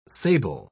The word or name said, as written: sable